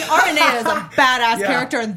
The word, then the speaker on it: Aranea: Aranea. I did say it right.